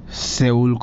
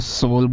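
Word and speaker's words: Seoul: वैसे भी उनके नाम्स डिफिकल्ट रहते हैं प्रोनाउंस करने के लिए, सेउल को सोल बोलते हैं वो लोग, आई डोंट नो.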